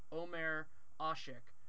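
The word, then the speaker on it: Ömer Aşık: Also, I looked up after we recorded it, and the NBA pronunciation guide has it as Omer Asik, and so that's going to be hopefully how I pronounce it from here on out.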